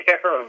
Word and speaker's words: Karam: It's Stephen Karam, yes.